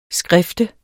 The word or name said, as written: SKRIFTES